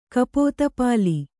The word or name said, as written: ಕಪೋತಪಾಲಿ